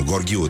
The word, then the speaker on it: Gorghiu: Numai Gorghiu trebuie să fie. Că nu e Raluca Turcan. Nu? – Poate e? – Că nu zici Burghiu.